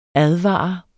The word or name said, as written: advare